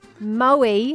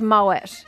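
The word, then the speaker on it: Moët: But Moe is not pronounced Moe. Moe train. – It's pronounced Moet.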